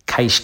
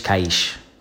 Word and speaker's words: Cascais: But over here in Portugal, my wife and I spent the day in a place called, it's spelled, rather, I'll spell it first, C-A-S-C-A-I-S. And in Portuguese, it's pronounced something like a variation of caix caix. And I used that many times today to annoy the hell out of my wife as if I was saying like cash in Brooklyn jargon, you know, like let's get some caix, caix caix, something like that.